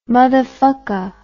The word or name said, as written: muthafucka